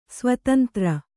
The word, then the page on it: ಸ್ವತಂತ್ರ: svatantra